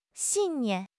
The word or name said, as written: 信念